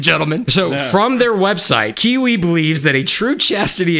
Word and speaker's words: Qiui: There is a company called Kiwi. It's spelled Q-I-U-I.